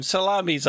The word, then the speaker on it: Salamis: How is it spelled? – S-A-L-A-M-I-S. – Well, I would say Salamis. – I really like Salami Island. Salami Island. – Once upon a time, Salami's Island was flush with Pokemon.